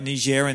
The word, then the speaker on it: Niger: For those who don't know, like I mentioned, they've been serving in a place called Nigeria. And we were chatting earlier about this place. I think I pronounced that right. Is that right? – Niger.